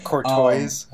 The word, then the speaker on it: Courtois: Whatever the fuck, however you pronounce his name, Courtois.